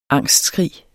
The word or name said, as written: angstskrig